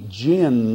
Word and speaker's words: jinn: Came to believe that he was possessed of a demon, a jinn. That's how I pronounce the Arabic word J-I-N-N.